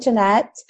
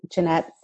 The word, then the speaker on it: Jeannette: It's all at Jet Set with Jeanette. – Jeanette is spelled J-E-A-N-N-E-T-T-E. – Yes. Okay. Yes. And at Twitter, it's my first and last name, Jeanette Seha. – Seha is C-E-J-A.